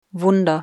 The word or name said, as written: Wunder